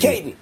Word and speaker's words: Caton: Nah, it's Caton. Just Caton. – Caton. Simple. Nice. All right. Simple. I was too fancy. I gave you the French pronunciation.